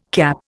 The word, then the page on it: CAP: Indian